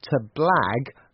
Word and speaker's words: blag: Yes, the pronunciation to blag, B-L-A-G, sounds like to brag, B-R-A-G, but it has a very different meaning, so watch out.